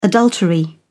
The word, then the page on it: adultery: British English